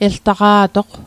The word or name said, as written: eltaqa'toq